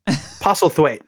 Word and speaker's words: Postlethwaite: Postlethwaite. Pete Postlethwaite. It has a kind of like apostle.